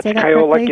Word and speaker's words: Kyolic: Now, the study that you did, or the studies, are all on, I'm probably going to say this wrong, is it Kyolic? Is that the brand? – Yeah, Kyolic is the manufacturer of aged garlic extract.